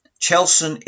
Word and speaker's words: Chelveston: Chelston is indeed the correct traditional pronunciation for the settlement of Chelveston.